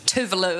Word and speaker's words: Tove Lo: I think she's, which is Tove Lo is actually how you say her name, and I've said it wrong on stage opening for her.